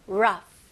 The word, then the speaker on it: rough: 여기서 O, U 너무 헷갈려 하지 마시고요. 그냥 U 발음. Rough. Rough. Rough. Very good. F 발음을 명확히 해주셔야 되고요. R도 힘을 주시면서.